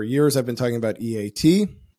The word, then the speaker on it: EEAT: I believe the proper pronunciation is EAT.